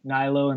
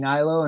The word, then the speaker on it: Hnilo: And my name is at Andy Hnilo. And my last name is spelled H as in Henry, N as in Nancy, ILO. And I'm also on Twitter at alituranaturals. And at Andy Hnilo, and it's all me.